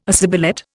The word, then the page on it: assibilate: Indian